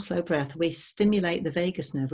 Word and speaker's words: vagus: the longest nerve in the body called the vagus nerve. It's spelled V-A-G-U-S, like Las Vegas, but slightly a different spelling.